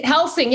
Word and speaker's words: Helsing: It's Helsing. Yeah, kind of like Van Helsing.